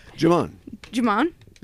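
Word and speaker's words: Djimon: don't call him Digimon Hutsu. – Oh, I'm sorry. How do you say his name? – Jimon? – Yeah. Okay. Jimon Hutsu.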